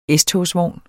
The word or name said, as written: S-togsvogn